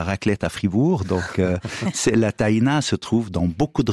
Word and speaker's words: tahina: Alors je vais me remettre à la langue arabe écrite, on dit tahina, donc c'est tahina. Après, on peut dire en libanais avec l'accent tahini, tahina, donc tout se dit, mais si on écrit le mot tahina en arabe, c'est tahina, et c'est comme ça qu'on devrait le prononcer tahina, avec un A.